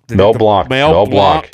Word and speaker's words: Mel Blanc: Let me say his name right. Blanc. Was it Blanc? – Mel Blanc. Yes. Yes.